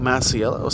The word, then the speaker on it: Masiello: Michelle Masiello, spelled M-I-C-H-E-L-E, and my last name is M-A-S-I-E-L-L-O, and I'm also put a few things on that Instagram.